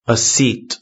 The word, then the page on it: a seat: British English